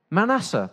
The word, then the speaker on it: Manasseh: If I get this wrong, is it Manasseh? Is that right? Manasseh, thank you. I knew it wasn't right. Manasseh, thank you.